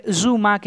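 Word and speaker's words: Zouma: Suma. Eh, Zuma, que es la correcta pronunciación, tuvo un malísimo partido, pero muy mal, muy mal partido.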